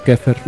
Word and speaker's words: Xepher: Pero dentro de lo que es el templo de Satán o dentro del esoterismo, hay un término que se escribe E F-E-R, que según el templo de Satán se pronuncia como. No, se pronuncia como Kefer, ¿no? Así es, es Kefer.